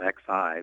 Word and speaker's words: Xi: Xi Jinping, it's spelled X-I, but pronounced Xi, soon to be the president of China, his current vice president of China.